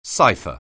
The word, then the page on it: cipher: anglais